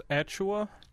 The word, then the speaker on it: Achiuwa: How do you pronounce that? Is it Achiuwa? Is that it? I believe it's Precious Achiuwa.